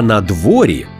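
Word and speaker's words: надворі: Ну, а «на дворі» наголос на «о» і пишемо разом – це прислівник, що означає «поза приміщенням на повітрі».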